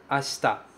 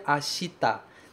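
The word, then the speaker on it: Asta (明日): Asta. Asta. Cuidado que nesse asta você não pronuncia ashita. Tem um i ali, logo depois do sh, mas você não pronuncia esse i. Ele é praticamente inexistente.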